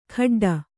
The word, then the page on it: ಖಡ್ಡ: kannada